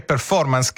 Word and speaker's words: performance: In inglese c'è anche performance, che tra l'altro con l'accento sulla O, per favore.